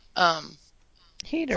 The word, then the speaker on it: Hader: Hader.